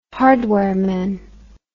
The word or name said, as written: hardware man